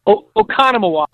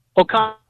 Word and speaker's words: Oconomowoc: Oconomowoc. Oconomowoc.